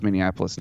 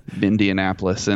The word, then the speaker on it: Minneapolis: I learned how to pronounce Minneapolis now. I'm not going to put the Mindianapolis in it like I used to with a few extra syllables.